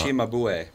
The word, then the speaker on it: Cimabue: It was, in fact, one of just 11 known paintings by Italian master Cimabue. Somebody apparently got a hold of it. The 10-inch high Christ mocked painting, first painting by Cimabue, was also known as Sieni de Pepo. – It's Cimabue.